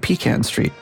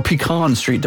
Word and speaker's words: Pecan Street: Sixth Street, Pecan Street, or Pecan Street, depending on how you like to pronounce it. – I say pecan. Is there like a preferred way of pronouncing that? I, I mean, I've always said pecan. I think it's regional.